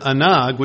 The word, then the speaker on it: anag: The word delight, the Hebrew word translated delight in Isaiah 58:13-14, is the Hebrew word oneg, spelled O-N-E-G. And it means exquisite delight and pleasant. It's taken from the root word anag, which is spelled A-N-A-G, A-N-A-G, anag.